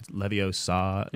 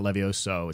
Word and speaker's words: Leviosa: Right, the whole joke about Leviosa. Not Levioso.